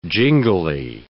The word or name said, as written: jingly